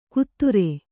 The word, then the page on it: ಕುದುರೆ: kudure